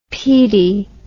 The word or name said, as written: Peattie